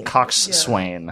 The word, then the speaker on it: coxswain: And that word is spelled, like, coxswain. – Coxswain. – Yeah. But you pronounce it coxswain? – Coxswain. – Oh, interesting. – I listened to a YouTube pronunciation of it before I came on the show, and she did it both ways. She said coxswain or coxswain.